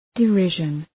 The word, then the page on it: derision: dı’rıʒən